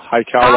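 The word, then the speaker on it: HiCal: And we'll make sure that, you know, Hickall Builder, is it Hickall? Did I pronounce that right? – Hi Cal, like Hawaii Cal.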